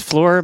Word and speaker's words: Fleur: It's Fleur Magali.